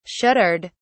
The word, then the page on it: shuddered: British English